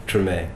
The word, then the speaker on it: Tremé: How do you say it? – Tremé.